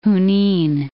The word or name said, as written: Junin